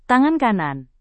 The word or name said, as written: tangan kanan